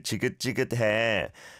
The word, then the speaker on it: 지긋지긋해: If I had to nitpick, I'd say the chia has to be pronounced a bit more like a j, right? So, 장마가 정말 지긋지긋해.